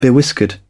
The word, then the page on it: bewhiskered: British English